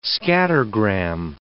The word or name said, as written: scattergram